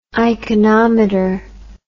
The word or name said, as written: iconometer